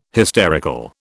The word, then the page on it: hysterical: British English